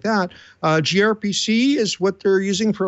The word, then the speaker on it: gRPC: We have gRPC, which is pronounced gRPC according to their own style guide.